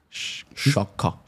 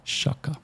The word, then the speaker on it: Xhaka: Aber du hast von ganz vielen Jungen immer wieder die korrekte Aussprache. Xhaka. – Xhaka. – Xhaka. Thailand Xhaka. – Er heisst ja Xhaka.